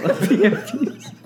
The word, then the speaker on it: PFP: It shits on the PSPs. – Oh, PFPs. I mean, you need to pronounce it right first before you can fight against it, boy.